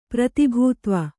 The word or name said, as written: ಪ್ರತಿಭೂತ್ವ